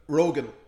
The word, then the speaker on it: Rogen: But how do you pronounce Seth Rogen? – Rogan. – Rogan. – Yeah, yeah. – But shouldn't that be with an A then? It's with an E.